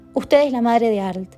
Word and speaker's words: Arlt: Entonces, satisfecho de ponerlo en un apuro al pedagogo, le dictaba: Arlt cargando la voz en la L.